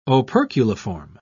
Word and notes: operculiform: British English

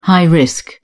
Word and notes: high-risk: British English

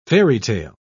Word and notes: fairytale: British English

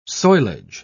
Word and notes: soilage: British English

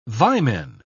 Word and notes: vimen: British English

